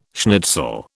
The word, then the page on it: schnitzel: British English